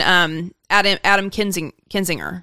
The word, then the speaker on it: Kinzinger: And even Adam Kinzinger.